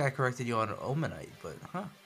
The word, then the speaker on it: Omanyte: Wait, is it the evolution of Omanyte? – Yes. – Okay, I know what that looks like. Fun fact for viewers at home, I used to pronounce Omanyte as Omanity until Mom had corrected me.